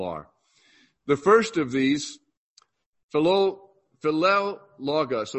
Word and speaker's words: Philologus: Greet Philologus. How do you say that, Fred? Philologus.